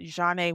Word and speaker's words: Jaunai: So at Jaunai Walker. And that is spelled J-A-U-N-A-I Walker.